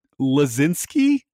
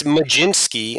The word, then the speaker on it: Mlodzinski: How would you think you would pronounce his last name, which is M-L-O-D-Z-I-N-S-K-I? How would you think that would be pronounced? – Well, I hope all of our listeners are playing along here, because if you're just hearing spell it, just looking at it is kind of intimidating. I think it's Lazinski. – Well, no, it's not. But it's a good. It's a better guess. It's Majinski, and I don't see a J in there.